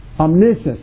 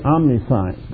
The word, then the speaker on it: omniscience: Omniscience is, actually, some people say omniscience, but the right pronunciation is omniscience. Omni meaning all, or unlimited. Science meaning knowledge. Unlimited knowledge. He knew that you were going to believe in Jesus Christ, and He chose you.